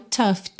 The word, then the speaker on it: tough: Outra palavrinha aqui que é fácil de pronunciar, mas difícil de saber que ela se pronuncia assim, que é o tough, T-O-U-G-H, tough, tough. Pensa naquele som bobo, a, e um F mesmo, tá?